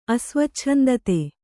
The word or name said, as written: ಅಸ್ವಚ್ಛಂದತೆ